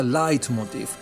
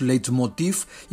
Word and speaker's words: leitmotiv: Por cierto, se escribe leitmotiv con V al final y en alemán se pronuncia leitmotiv. Pero ya se ha castellanizado el término y la Real Academia nos permite ya que pronunciemos leitmotiv y que digamos leitmotivs en plural.